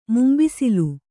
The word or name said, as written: ಮುಂಬಿಸಿಲ್